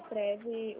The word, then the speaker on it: Prev: O Prev, o V é mudo.